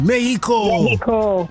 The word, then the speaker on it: Mexico: When you get there, don't pronounce the X the way that we do. Just say Mexico.